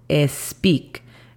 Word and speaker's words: speak: Speak de hablar sería S-P-I-K. Speak. Recuerden y noten bien la pronunciación de la S. No estoy diciendo speak, estoy diciendo speak.